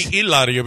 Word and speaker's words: Hilaria: Her name would be Hilaria because you don't pronounce the H.